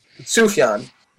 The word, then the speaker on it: Sufjan: Sufjan.